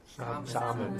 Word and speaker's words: salmon: How do you say summon? Summon? – Salmon.